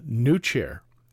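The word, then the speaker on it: PneuChair: In the Human Engineering Research Lab, they've developed what they call the new chair, except that's spelled P-N-E-U chair, like pneumatic.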